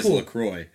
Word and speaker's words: LaCroix: And then it's Cran Raz Seltzer, which is, I call it LaCroix. I don't know if that's the proper way to say that. That is the proper way to say it. It is LaCroix.